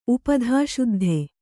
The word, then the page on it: ಉಪಧಾಶುದ್ಧ: Kannada